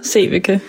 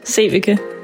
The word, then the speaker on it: Sevecke: Rikke Lindva Sevecke. – One more time. – Rikke Lindva Sevecke.